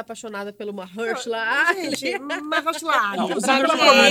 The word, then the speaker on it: Mahershala Ali: Mahershala Ali. Mahershala Ali.